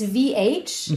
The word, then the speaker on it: V.AGE: Gesch geschrieben wird es V.AGE. – Genau, das ist VH.